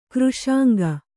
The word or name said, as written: ಖರಸಿಂಗ